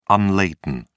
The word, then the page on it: unladen: British English